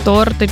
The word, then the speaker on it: торти: Так, перше, таке смачненьке, торти чи торти? – Торти чи торти? – Ага. – Торти. – Торти, правильно. Запам'ятаємо, не торти, а торти.